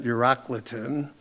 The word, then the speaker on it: Euroclydon: But not long after, a tempestuous headwind arose called a Euroclydon, or some people pronounce it Eurocliton.